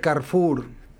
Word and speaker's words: Carrefour: Y se pronunciaría Carrefour.